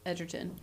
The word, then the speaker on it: Egerton: And Taron Egerton, Edgerton, as Sir Elton says. Yeah. Edgerton. – Soft G.